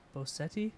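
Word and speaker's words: Bowsette: Is absolutely no idea what it is or really even how to say it. Bosset? – Oh, Bosetti? – Bosetti? – Yeah.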